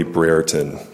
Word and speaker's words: Brereton: For the record, my name is Charlie Brereton. Last name is spelled B-R-E-R-E-T-O-N.